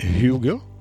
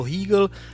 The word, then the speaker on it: Hugel: Podle mě Hugel? – No, Hugel nebo Hegel, ale asi by to měl být člověče Hugel.